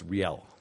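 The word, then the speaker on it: Rielle: it's Riley Hunter, I think it's pronounced. – It's pronounced Riel. – Riel, okay. Riel, I was told Riley by somebody else, but Riel Hunter.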